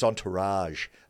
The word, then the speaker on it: entourage: I think it's entourage. It's like a French word. It's almost it's a soft G. Are you sure? Yeah, not a, it's a soft G, not a hard G.